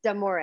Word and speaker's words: D'Amore: It's D'Amore.